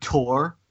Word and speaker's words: Thor: But isn't Thor the actual pronunciation like Tor, more of a T sound? In the original, Anthony Hopkins would pronounce it like Tor.